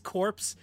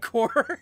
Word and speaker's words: corps: pronouncing C-O-R-P-S corpse instead of core.